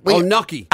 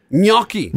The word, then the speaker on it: gnocchi: Gnocchi. – Apparently, you're supposed to put a Y in it. So apparently, it's like gnocchi. – Gnocchi. – Gnocchi is supposed to be how you pronounce that.